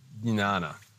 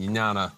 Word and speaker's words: jnana: whereas the term in Sanskrit is jnana. And jnana, I think I was pronouncing it right, J-N-A-N-A. I can never get that one right, because J-N is not a thing we use in our language, English.